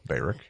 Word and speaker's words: Barak: It looks like Barack, but it's pronounced Barak.